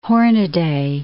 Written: Hornaday

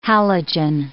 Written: halogen

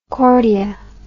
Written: cordia

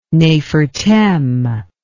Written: nefertem

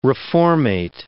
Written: reformate

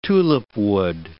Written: tulipwood

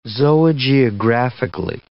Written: zoogeographically

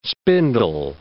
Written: spindle

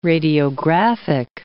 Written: radiographic

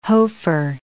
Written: Hofer

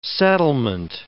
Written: settlement

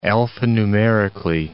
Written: alphanumerically

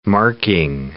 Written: marking

